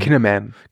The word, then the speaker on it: Kinnaman: Kinnaman? Ich kann den immer nicht aussprechen. – Kinnaman.